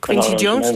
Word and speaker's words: Quincy Jones: Oh, Quincy Jones, die kennen we allemaal van Michael Jackson, toch?